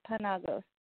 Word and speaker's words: Panagos: And I know my last name is really hard to pronounce, so it's just for the fans out there, it's Panagos.